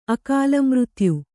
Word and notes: ಅಕಾಲಮೃತ್ಯು: kannada

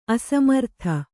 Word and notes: ಅಸಮರ್ಥ: asamartha

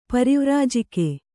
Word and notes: ಪರಿವ್ರಾಜಕ: pari vrājaka

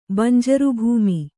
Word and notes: ಬಂಜರುಭೂಮಿ: Kannada

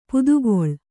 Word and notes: ಪಿತ್ತಕಲ್ಲು: pitta kallu

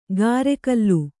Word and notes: ಕರೆಗಲ್ಲು: karegallu

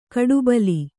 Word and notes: ಕಡುಬಲಿ: Kannada

